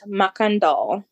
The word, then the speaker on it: Macandal: So, a man named Macandal, which stuck out to me because living next door to Macanda, Illinois, mm-hmm. That's pretty much how that's spelled, but with an L on the end.